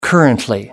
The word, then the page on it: currently: British English